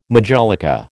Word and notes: majolica: American English

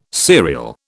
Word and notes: cereal: British English

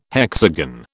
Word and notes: hexagon: British English

